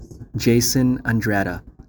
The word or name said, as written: Jason Andrada